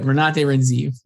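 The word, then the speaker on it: Renate Reinsve: Renate Renzive.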